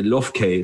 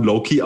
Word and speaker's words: low-key: Hier Lofkay, das verstehe ich nicht. Also das ist ein englisches Wort, das heißt... – Langlos. – Das heißt, spricht man Low-Key aus und das ist so, wenn eine Beziehung zwischen zwei Menschen Low-Key ist, dann ist es so ein bisschen undercover.